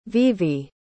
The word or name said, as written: vevay